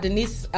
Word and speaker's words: D'Nise: And let me tell y'all, Denise, my music name, my artist name is spelled D'NISE.